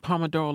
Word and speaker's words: Pomodoro: He said, it's pretty low-key and based on the Pomodoro technique. I don't know if I'm saying that right. – Pomodoro like the sauce? – Or like the dog.